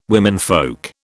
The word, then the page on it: womenfolk: British English